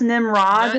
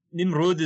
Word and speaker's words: Nimrud: Is it often pronounced Nimrod? – Not tremendously, other than Nimrud is how I usually see it rendered in Arabic as well. And then it becomes a long oo.